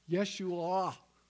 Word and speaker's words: Yeshua: The Hebrew says, it's hard to pronounce, but it if you do it the way I was taught, it's Yeshua. There's a lion at the end.